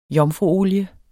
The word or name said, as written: jomfruolie